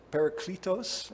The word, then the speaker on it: Parakletos: Parakletos. P-A-R-A-K-L-E-T-O-S.